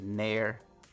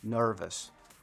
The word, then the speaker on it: nervous: no se dice near. No se dice near, se dice no, nervous, nervous. Eso es una de las cosas más importantes.